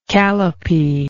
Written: calipee

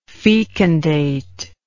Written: fecundatory

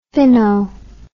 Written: finno

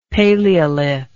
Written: paleolith

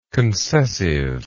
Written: concessively

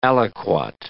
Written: aliquot

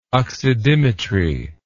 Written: oxidimetry

